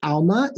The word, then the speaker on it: Alma: So my name is Alma, A-L-M-A. My last name is spelt O-H-E-N-E-O-P-A-R-E.